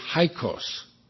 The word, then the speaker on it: Hyksos: They're referred to as Hyksos, I think is the proper pronunciation, H-Y-K-S-O-S.